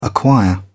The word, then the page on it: acquire: British English